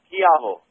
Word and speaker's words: Schiavo: Yes, it's pronounced Chiavo. – Oh, yes, correct.